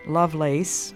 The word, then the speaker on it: Lovelace: This is your friend Elizabeth Elliott talking today with my sister-in-law, Lovelace Howard. Her name is spelled L-O-V-E-L-A-C-E. That's her first name, but we pronounce it Lovelace usually.